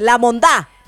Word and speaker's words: La Mondá: No, tienes que decirlo bien. La Mondá. – Mondá. – Maldita sea, nunca todo. – Dilo. Con acento en la A. – La Mondá. – Eso, como si fueras costeña de Colombia.